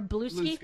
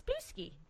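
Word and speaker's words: Bluesky: I think we should pronounce it Blue Ski. – That's what I pronounce it as. And it's friendlier. – It is. It's Blusky.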